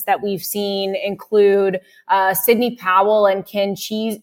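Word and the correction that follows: Chesebro: or Chesbro, I should say. Now I know how to pronounce his name. – It's the cheese. – The cheese. – But it's Chesbro.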